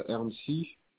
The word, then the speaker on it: Erncy: Et le prénom, c'est Ernsy. Ça s'écrit E-R-N-C-Y.